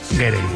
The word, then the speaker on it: Gere: pensando yo que si te apellidas Ger, bueno, ¿cómo se dice Ger o Gerde? – Gere. Me ha hecho gracia porque has dicho Richard Gere y luego el hijo era Gere.